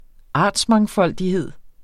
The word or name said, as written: artsmangfoldighed